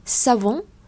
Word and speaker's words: savon: tiếng pháp chúng ta không đọc là xà vùng xà vùng mà savon savon savon